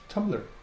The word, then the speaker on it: Tumblr: Tumblr.